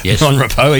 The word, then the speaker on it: Mon Repos: It's Mon Repos. Mon Repos, even though it's spelled, right, as you said, R-E-P-O-S.